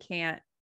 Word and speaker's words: can't: And this one is really hard to explain too, because it's like, okay, there's a T, but you just don't pronounce it for can't. Why? Isn't that can? It's really, really confusing and can absolutely cause big misunderstandings if you're not careful with it. So yeah, can and can't. If you just say it like that, can, can't, they're very similar.